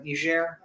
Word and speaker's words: Niger: It may be, it may not be Niger, uh, Niger. I believe is actually the correct pronunciation, but it may not be Niger right now.